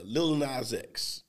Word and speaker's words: Lil Nas X: And so this is actually, you know, Lil Nas X, right? Is that how you say his name? – Yeah.